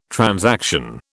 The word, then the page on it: transaction: British English